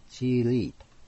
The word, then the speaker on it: 激励: 現代ではチーリーと発音するわけですね。